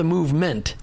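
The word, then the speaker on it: Movemint: And why do you not call it the Movement? I mean, it's spelled MOVE and then M-I-N-T, Mint. – Right, so MOVE, like get moving, healthy. And mint, like M-I-N-T, minting a coin.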